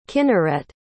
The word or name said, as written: kinneret